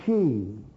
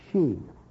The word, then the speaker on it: Sidhe: The people of the goddess Donna, Tuatha De Danann, or the She, the way you pronounce S-I-D-H-E, She.